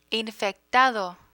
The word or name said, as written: infectado